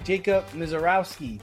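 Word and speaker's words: Jacob Misiorowski: The Brewers phenom pitcher, see if I get his name right, Jacob Mizorowski, the Miz.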